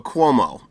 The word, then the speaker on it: Cuomo: Comeo. The governor of... – I don't know who that is. – The governor of New York? – Oh, Cuomo.